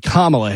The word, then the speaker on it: Kamala: And she said our current Vice President, I can never get her name right, and she said Kamala Harris, it's actually Kamala Harris.